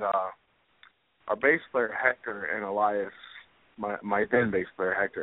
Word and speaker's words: Elias: So when does it come to the point that you meet, and I don't, tell me if I pronounce his name wrong, because I don't want to pronounce wrong. Was it Elias? – Elias. – Elias, okay.